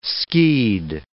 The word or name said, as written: skied